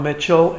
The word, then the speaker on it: Mitchell: Bob Mitchell, M-I-T-C-H-E-L-L.